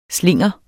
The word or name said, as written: slinger